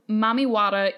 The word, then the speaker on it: Mami Wata: Mami Wata is pidgin English pronunciation of the word mammy or mother and water, obviously.